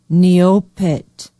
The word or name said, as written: Neopit